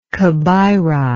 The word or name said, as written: kabeiri